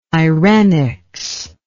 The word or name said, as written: irenics